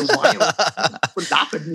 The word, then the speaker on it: Viome: I would say Viome, but don't feel bad.